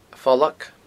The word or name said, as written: A' Phollag